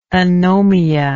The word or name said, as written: anomia